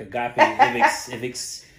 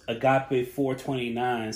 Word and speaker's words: Agape 429: It looked like agape ivix ivix. I'm like I didn't know what that was, but the fact that you broke it down, so it's agape 429.